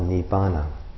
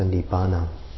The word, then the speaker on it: Nibbana: In Pali it's called Nibbana, the way that Pali and Sanskrit kind of are closely connected, but pronounced a little bit differently. So Nibbana.